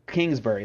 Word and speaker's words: Kingsbury: Kingsbury, that's how it's pronounced.